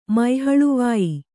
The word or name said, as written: ಮೈಹಳುವಾಯಿ